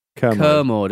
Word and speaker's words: Kermode: Kermode? Is it Kermode? No, it's Kermode, isn't it? – Yeah, a commode is a toilet, so presumably it doesn't want to be pronounced like a toilet.